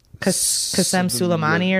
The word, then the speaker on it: Qasem Soleimani: And it's like, there's a lot more, how do you say his name? Qasem Soleimani or something sure. – like that? – Yeah, that sounds good.